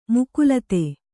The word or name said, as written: ಮುಕುಲಿತ